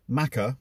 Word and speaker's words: Machar: And it's called Maca, and it's spelled M-A-C-H-A-R. It's not like Paul McCartney type Maca, but it's M-A-C-H-A-R, Portrait of a Saint.